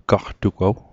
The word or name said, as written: gaqtugaw